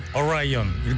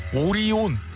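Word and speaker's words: Orion: 뭐 미국 친구들은 오라이언 이렇게 발음하죠? 우리는 오리온.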